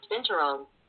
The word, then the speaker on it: binturong: That's pronounced binturong.